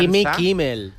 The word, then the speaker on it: Jimmy Kimmel: Mandó ese vídeo a Jim Kimmel. Como los americanos tienen pasta gansa. – Jimmy Kimmel. – Bueno, tú pronuncias bien, yo lo no, hago de rollo de Menorca.